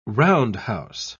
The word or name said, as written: roundhouse